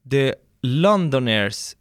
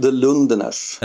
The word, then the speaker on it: The Lundeners: och hur utvecklas the Londoners, säg ut, jag har haft panik inför eh, uttalet här när jag suttit innan med avsnittet. – Ja, vi själva säger The Lundeners.